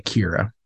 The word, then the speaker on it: Ciara: Nellie McKay plays, was it pronounced Ciara? – I think it was Kira. I think they pronounced it as Kira in the movie, yeah, but it's spelled like Ciara, yeah, and maybe an Irish spelling, I don't know. – Oh, maybe, yeah. – But yeah, they pronounce it like Kira, weirdly.